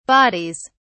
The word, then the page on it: bodies: British English